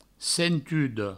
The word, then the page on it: sentuda: sentudo